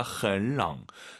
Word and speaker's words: 很冷: 삼성삼성이니까 앞에 흔 올라갑니다. 很冷.